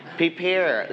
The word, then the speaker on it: prepare: Prepare. Let's check together. Everybody say it together. Prepare. – Prepare. – Prepare. Okay, so we stretch on the second syllable.